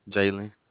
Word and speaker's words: Jalen: Jalen. – Jalen? – Mm-hmm. – J-A-Y-L-Y-N? – J-A-L-E-N.